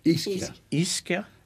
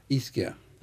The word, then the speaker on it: Ischia: This, but how do you say the name of the island? – Ischia. – Ischia.